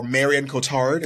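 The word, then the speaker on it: Marion Cotillard: In the film, Will is married to French actress Marianne Cotard, or Marion Cotard, as I would like to say, because that was a butchering of pronunciation.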